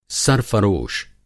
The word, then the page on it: सरफ़रोश: سرفروش